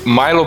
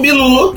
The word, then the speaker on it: Milu: É o dono do Milo pros americanos e Milu pro resto da face da Terra. – Ou seja, do Milu, que é o jeito certo de falar.